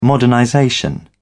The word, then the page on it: modernization: British English